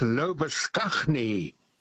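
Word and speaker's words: Labuschagne: Lobashane. – Oh, yes. – Now, Mr. Lobashane's name in South Africa, where I spent my youth, was and still is pronounced as Lobaskagni.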